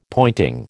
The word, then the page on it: pointing: American English